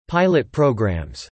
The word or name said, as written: pilot programs